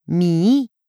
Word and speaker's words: mihi: La forma latina mihi sonaba mihi, porque la H en latín no se pronunciaba en general.